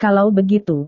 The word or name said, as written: kalau begitu